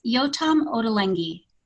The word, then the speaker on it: Yotam Ottolenghi: Absolutely, it's Yotam Ottolenghi.